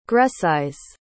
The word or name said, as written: grecize